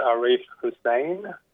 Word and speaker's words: Arif Hussein: Yeah, my name is Arif Hussein, and I'm a senior lawyer at the Human Rights Law Center, working to advance human rights frameworks in Australia.